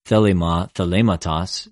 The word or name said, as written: θελημα,θεληματος